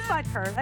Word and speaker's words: Butker: Do I have that right? Oh, I have a cheat sheet. Wait, it's on a paper towel. I can't read it. – Right, yeah, it is Butker. I said it right.